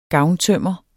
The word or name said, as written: gavntømmer